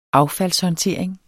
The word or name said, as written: affaldshåndtering